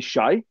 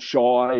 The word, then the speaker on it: Shai: His name is Shay, and he gets called Shy and all these different names.